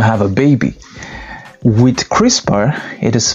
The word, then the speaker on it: CRISPR: It is pronounced CRISPR.